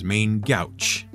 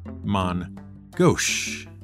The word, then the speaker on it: main gauche: The most prominent and well-known type of parrying dagger was one that many gamers have seen written down and often mispronounced as main gouch, but is more correctly pronounced as mon gauche. It's a French phrase, and simply means using the left hand.